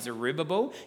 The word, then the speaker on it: Zerubbabel: Nehemiah and Ezra and Haggai and Zerubbabel. Yes, Zerubbabel's a real name and that's how you say it.